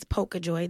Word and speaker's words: Pocahjoi: My Instagram name is Pocahjoi.